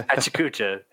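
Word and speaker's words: Pecha Kucha: People correct me all the time, but it's Pechacucha, and I'm going to stick with it.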